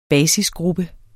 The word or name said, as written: basisgruppe